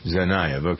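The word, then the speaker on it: Tsarnaev: They also discussed Sarnaev, is that how we pronounce his name? – Sarnaev.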